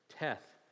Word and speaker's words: Teth: And looking at that letter, Teth. It's pronounced Tet, and I can pronounce this one.